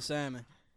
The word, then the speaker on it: salmon: I'm eating salmon. – He been impressed talking about he eating salmon all day. – Salmon, don't pronounce the L.